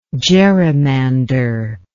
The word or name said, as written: gerrymanderer